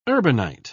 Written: urbanite

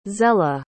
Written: zela

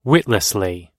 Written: witlessly

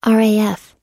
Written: raf

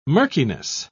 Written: murkiness

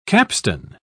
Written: capstan

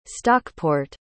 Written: stockport

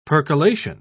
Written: percolation

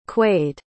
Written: quade

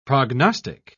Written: prognostic

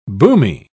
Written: boomy

